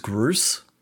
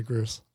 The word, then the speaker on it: Groose: Even Goose, who's like, you know, ultimate like Chad character. – Say it properly, please. His name is Groose. – Oh, sorry, Groose.